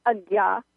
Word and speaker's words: Ajna: So one of the chakras is in the middle of your head, and it's called the Ajna Chakra. And the Ajna Chakra, that's spelled A-J-N-A, by the way, Ajna Chakra.